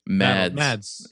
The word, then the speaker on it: Mads: You said Matt Nicholson? – Mads. M-A-D-S.